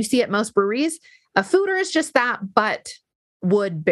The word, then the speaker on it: foeder: I keep seeing fooder beers, lagers, I think, in Summit County breweries. What are there? So a fooder, which is spelled F-O-E-D-E-R, but pronounced food-er, basically is just like an oak barrel, like a wood barrel used to age a beer in, but it's a lot bigger.